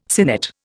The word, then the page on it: sinnet: Indian